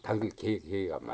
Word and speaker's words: 당글개: 당글개입니까? 당글애입니까? – 당글개가 맞죠.